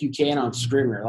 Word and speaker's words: Scrivener: Scrivener.